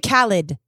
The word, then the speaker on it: Khaled: It's DJ Khaled.